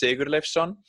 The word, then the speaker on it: Sigurleifsson: So my last name is pronounced Sigurleifsson, but I can promise you, you're not the first person to skip it.